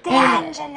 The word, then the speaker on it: D'Angelo: I'm like, how do I say her last name? – D'Angelo.